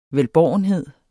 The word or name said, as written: velbårenhed